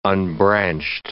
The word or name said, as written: unbranched